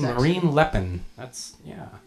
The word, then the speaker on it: Le Pen: Le Pen? Is that her name? – Le Pen. – Le Pen? Margaret Le Pen or something? – Yeah. – Lupin? – Oh, man.